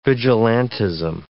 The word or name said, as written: vigilantism